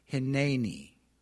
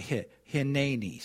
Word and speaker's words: Hineni: It's spelled H-I-N-E-N-I. It's a Hebrew word. Okay. And I believe, I'll probably misspell it, but I believe you pronounce it Hineni. The N-E would be like N-A-Y. Like hi, but Hineni. So say that with me. Hineni.